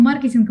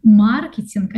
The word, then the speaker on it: маркетинг: Подавала документы в колледж на управление, на менеджмент, что такое маркетинг, вообще даже не знала. И, кстати, впервые тогда услышала, что маркетинг, а не маркетинг, что в профессиональной среде надо делать именно так ударение.